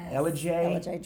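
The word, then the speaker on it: Ellijay: Yes, and I think I've learned how to say this. You folks listening, tell me. We're going to LJ. – LJ, Georgia. – And we asked last week, how do you say that? And we had some folks that helped us on that. – We were saying it a little bit wrong.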